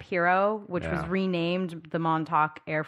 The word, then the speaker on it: Montauk: The Montauk Project. I'm saying that right, right? – Montauk? – Montauk? Yeah. Montauk is this series of secret U.S.